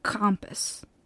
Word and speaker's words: Krampus: He leaves the bad kids to his counterpart, the yin to his yang, Krampus. Or as it's more properly pronounced, but also probably very badly pronounced because I'm not German, Krampus.